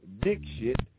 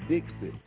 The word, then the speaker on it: Dixit: mispronounced dick shit, despite being told by the lead anchor that it's pronounced Dixit. D-I-X-I-T, Dixit.